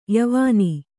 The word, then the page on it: ಯವನಿ: yavani